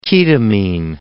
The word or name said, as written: ketamine